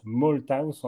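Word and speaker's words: Malthouse: Et puis le troisième, euh, le troisième grand penseur est également américain, Edward Malthaus, on prononce Malthaus en anglais.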